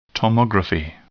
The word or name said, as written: tomography